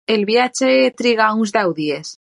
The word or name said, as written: triga